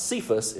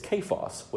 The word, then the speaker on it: Kephas: It's not Cephas, it's Kephas, which I find quite interesting, which messes with my ears, right? You hear Kephas and not Cephas, and it kind of drives you crazy, but it's Kephas, which I thought was interesting.